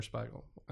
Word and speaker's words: Spiegel: So Spiegel or Spiegel. I can never get that right, but I'm using the German pronunciation, Spiegel.